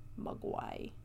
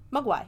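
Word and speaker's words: Mogwai: I mean, pronunciation is a long O, not a short U. Mugwai. Mugwai, yeah, Mugwai.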